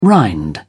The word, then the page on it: rind: British English